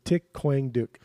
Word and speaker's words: Thich Quang Duc: So Thich Quang Duc. – Yeah. There you go. So okay. That's how you say it.